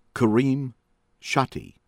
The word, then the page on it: AL-MEBAZZA, FOUAD: foo- AHD al mm- BAH- zah